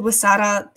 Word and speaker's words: Huesera: It's called Wasara the Bone Woman. That's spelled Huesera, which just means the Bone Woman.